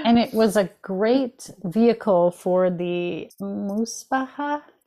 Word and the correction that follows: Musabacha: Did I say that right? – Musabacha.